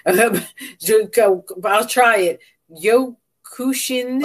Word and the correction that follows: Kyokushin: Oh, Kyokushin.